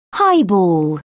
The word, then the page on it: highball: British English